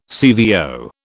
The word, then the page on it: CVO: British English